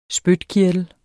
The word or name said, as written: spytkirtler